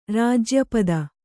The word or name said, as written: ರಾಜ್ಯಪದ